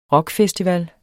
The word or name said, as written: rockfestival